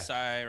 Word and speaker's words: Isai: I think the guy that plays Bob, Asai Morales, am I saying that right? – Yeah, I was writing his name down for this, and I have no idea how to pronounce it. – I think it's Isai.